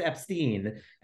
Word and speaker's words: Epstein: well, actually, it's pronounced Epstein, and, you know, draw attention to his Jewish name.